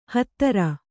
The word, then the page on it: ಹತ್ತರ: kannada